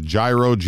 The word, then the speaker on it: gyro: Gyro, right? – Gyro. It's almost without the G. It's like a G-R or G-Y sound.